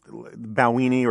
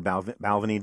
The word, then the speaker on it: Balvenie: I like this Balwini or Balvenie, depending on how you want to pronounce it.